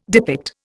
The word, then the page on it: depict: Indian